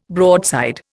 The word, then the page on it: broadside: Indian